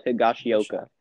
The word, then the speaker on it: Higashioka: And then the backup catcher, not even going to try to pronounce his name, Higashioka. – You're close, Kyle Higashioka.